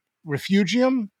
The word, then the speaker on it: refugium: I'd never seen the word refugium. Am I saying that correctly? – Yes, you've got it.